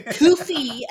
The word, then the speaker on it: Ko-fi: we're so entertaining we have a Ko-fi account actually it's pronounced kofi but i like calling it kofi